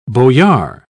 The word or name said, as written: boyar